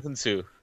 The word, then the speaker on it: Hounsou: Is it Djimon Hounsou? – How do you say his name? Hounsou.